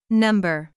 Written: number

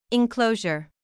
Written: enclosure

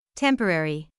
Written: temporary

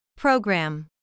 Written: program

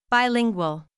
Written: bilingual

